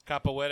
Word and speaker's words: Capoeira: Capoeira action.